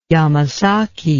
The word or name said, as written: yamasaki